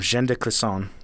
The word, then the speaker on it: Jeanne de Clisson: So the lady that I'll be talking about today is named Jeanne de Clisson.